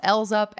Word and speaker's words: EL7Z UP: All right, next up, we have L's Up, and this is spelled E-L-7-Z-U-P. They debuted on September 14th, and it is pronounced L's Up, and it is Puzzle Backwards, because these are the seven members.